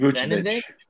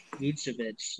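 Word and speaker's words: Vučević: Vucevic. Vucevic. – Vucevic.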